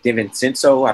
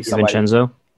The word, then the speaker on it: DiVincenzo: Dante DiVincenzo. I don't know if I pronounced that correctly. – DiVincenzo. – There we go.